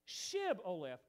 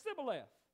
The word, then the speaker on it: Shibboleth: They knew that the Ephraimites couldn't pronounce Shib-Oleth, which would have been that shin on the front of their word there. And that word alone means a flowing stream. Instead, because of the dialect, they couldn't pronounce Shibboleth. Because they grew up in South Israel, all right? They didn't say it. They said Shibboleth. They used the sin instead of the shin.